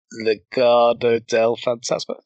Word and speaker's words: Legado del Fantasma: Bronson Reed and MSK versus Legado del Fantasma. Did I say that right? – Correct.